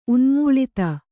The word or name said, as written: ಉನ್ಮೂಳಿತ